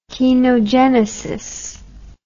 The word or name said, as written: kenogenesis